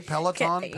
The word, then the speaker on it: Peloton: Peloton? – Peloton, say it? – Yeah. – Okay. Peloton.